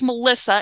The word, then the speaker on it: Malissa: And if you want that again, um, you can contact me so my first name is Malissa, and that is spelled M A L I S S A, last name H U D as in David, S O N.